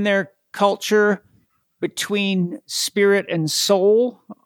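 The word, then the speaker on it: sál: And it's spelled S-A-L. And the A has a little accent mark over it. So it's pronounced soul. Soul.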